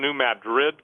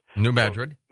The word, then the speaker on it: New Madrid: I believe that once the New Madrid goes off, I pronounced that wrong, New Madrid. – New Madrid.